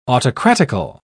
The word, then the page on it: autocratical: British English